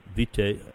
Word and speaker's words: vita: So I am looking at your curriculum vitae. How do you pronounce that anyway? I've never learned that. Curriculum vitae? Or? – I always say vita in the singular.